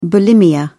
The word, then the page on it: bulimia: British English